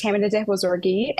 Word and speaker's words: Tahmineh Dehbozorgi: My name is Tamina Debozorgi, and you pronounce it absolutely perfectly.